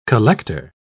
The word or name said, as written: collector